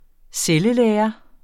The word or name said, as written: cellelære